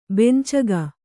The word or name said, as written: ಬೆಂಚಗ